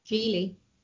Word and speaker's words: Gigli: Apparently it's supposed to be pronounced Gigli. – Yeah. – Sorry. – Geely. – According to the phonetic thing on Wikipedia.